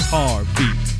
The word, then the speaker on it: Harvey: My name is Harvey, pronounced with an R-V.